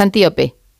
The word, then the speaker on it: Antíope: Pero me gustaría, lo he dicho mal el nombre, es Antíope. – Antíope, ¿verdad? – Antíope, igual. Antíope, no, que la he llamado yo como un animal y no puede ser, Antíope.